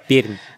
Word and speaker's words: Пермь: Во-первых, я бы посоветовал произносить это слово с мягкой буквой «Р». – Пермь.